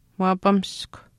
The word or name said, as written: waapamiskw